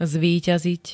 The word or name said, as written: zvíťaziť